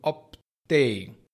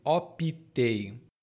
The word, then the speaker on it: optei: E aqui, observe bem, minha pronúncia está sendo optei, mas muitas pessoas dizem optei.